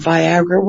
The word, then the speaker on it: Viagra: And you talk about Viagra, which rhymes with Niagara, which is an interesting concept.